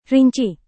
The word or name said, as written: rinci